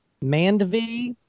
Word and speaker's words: Mandvi: Also, Asif, how do you say his last name? Mandvi, but he's on The Daily Show.